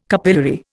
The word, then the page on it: capillary: Indian